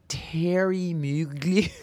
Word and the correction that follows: Thierry Mugler: Thierry Mugler, whatever.